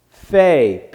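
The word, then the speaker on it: Pei (פ): This little dot right here, when that dot is in there, and for my way of remembering things, there's food in the mouth, so you pronounce it Pei, and that is the typical, typical pronunciation of that. However, there are other little squiggles and things they put around to tell them how it is. But if you take that off of there, then it's pronounced Fay, P-H-A-Y. But that's not the normal. The normal way has that in there, and it's pronounced Pei.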